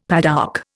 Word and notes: padouk: Indian

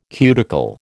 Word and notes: cuticle: American English

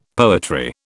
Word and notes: poetry: British English

